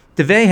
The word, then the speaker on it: duvet: duvet has a silent T, and it's kind of a French word.